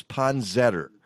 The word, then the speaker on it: poinsettia: I know, you say poinsettia. We determined on this show many years ago that the proper way of pronouncing it is poinsettor. So poinsettor is the name of the plant. – Poinsettor? – Poinsettor.